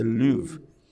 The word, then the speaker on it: Louvre: It's like, I don't know, go to the Louvre. Is that how we pronounce it? The Louvre.